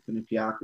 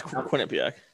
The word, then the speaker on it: Quinnipiac: He was the captain of his NCAA team in Quinnipiac, or I'm saying that wrong, but yeah. – Yeah, no, that's Quinnipiac.